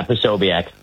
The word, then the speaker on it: Posobiec: Posobiec. – How do you pronounce it? – Yeah, Posobiec.